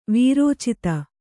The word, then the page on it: ವೀರೋಚಿತ: vīrōcita